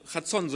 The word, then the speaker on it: Chazon: Der hebräische Begriff dafür ist Chazon. Ja, Chazon, so wird es ausgesprochen und kann auch oder bedeutet Vision,